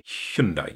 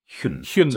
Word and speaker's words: Hyundai: And it's not just us that are being told off for pronouncing it wrong, but it's a Hyundai, isn't it? Or Hyundai. – Hyundai, yeah. Hyundai. So it should have the as the noise.